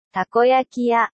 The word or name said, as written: takoyaki shop restaurant